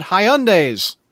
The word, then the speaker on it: Hyundai: So, keeping with movie-related news, you've got something about Hyundais. – Hyundai. – About what? – Hyundai. That's how that's pronounced.